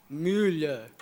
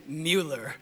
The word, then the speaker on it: Müller: Almost like the Chinese word for fish, Müller, right? Is that right, Andrew? All right, pretty much. And I will stop speaking in tongues now, so I'm not going to pronounce it Müller. I'm just going to pronounce it the good old American Mueller.